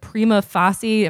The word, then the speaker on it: prima facie: Still, getting the band to comment about such a prima facie, am I pronouncing that right? – Prima facie.